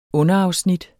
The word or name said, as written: underafsnit